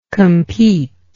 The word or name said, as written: competingly